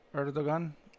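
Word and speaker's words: Erdoğan: Erdogan. – Erdogan told Musk over a video conference that he will receive an NFT made of satellite art created by 5,000 school children.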